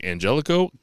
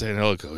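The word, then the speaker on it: Angélico: Blackpool Combat Club faces Angelico. Butcher and the Blade versus Angelico. It was pretty funny listening to Regal. He was like, Angelico? And they're like, yeah, no, it's Angelico.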